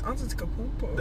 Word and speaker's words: Antetokounmpo: Anatatulu, what? – That's not his last name? – It's Antetokounmpo.